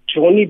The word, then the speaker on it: Johnny: Alternatively, my email address is Johnny Bock.